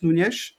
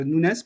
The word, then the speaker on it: Nunes: Mateus Nunes, euh, Nunes pardon, je, suis mauvais en prononciation,